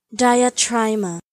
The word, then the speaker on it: Diatryma: And otherwise, we'll just be back right after I play this, which is the correct pronunciation of whatever that bird was called. We'll all learn it together right now. – Diatryma.